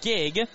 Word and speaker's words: Gieg: G-I-E-G, pronounced Gigg, as Parker Kelly will stride to the plate.